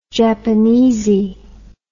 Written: japanesy

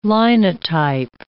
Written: Linotype

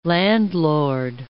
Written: landlord

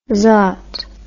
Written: zot

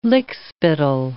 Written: lickspittle

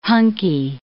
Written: Hunkie

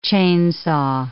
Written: chainsaw

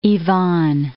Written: Ivan III

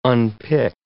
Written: unpick

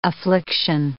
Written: affliction

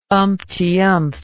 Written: umptyumpth

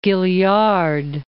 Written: Gaillard

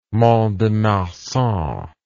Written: Mont-de-Marsan

